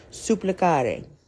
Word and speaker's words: supplicare: Even though it's a noun, supplication comes from the Latin verb, and wait for this fancy pronunciation, supplicare.